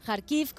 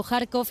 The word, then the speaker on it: Kharkiv: Kharkiv, como dice ella, también pronunciado Kharkov,